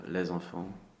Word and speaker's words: les enfants: You can have schools who say that les, L-E-S should be close, pronounced as les enfants, but for me it's very academic. And in the French usual way of pronouncing in the street, les enfants, for me it's better, les enfants.